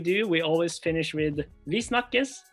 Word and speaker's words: Vi snakkes: Vi snakkes. – Oh, if I say it, I'm going to be like... – You gotta try. – Okay, tell me again. – Vi snakkes. – Vi snakkes.